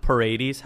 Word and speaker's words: Paredes: They added Isak Paredes. How is that pronunciation? – Yep.